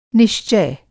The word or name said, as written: निश्चय